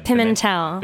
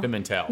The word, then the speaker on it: Pimentel: Pimentel. – Pimentel. – Like pimento cheese, but with a tel at the end instead.